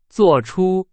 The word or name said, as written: 作出